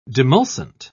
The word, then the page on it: demulcent: British English